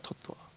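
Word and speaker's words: jiva tattva: Yes, Jiva Totwa. Did I pronounce that right? Totwa. – Yes, Prabhu.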